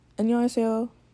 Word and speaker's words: 안녕하세요: But, you know, a quick bow, run all the words together, 안녕하세요.